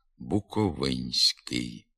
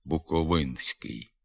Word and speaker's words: буковинський: Є чимало прикметників, у яких перед суфіксом «ськ» стоїть звук «н». Його треба обов'язково пом'якшувати, тому що він у позиції перед пом'якшеним звуком «с». Наприклад, «буковинський». Дехто з мовців не пом'якшує цей «н» перед «ськи», вимовляє «буковинський». Така вимова є порушенням літературних норм.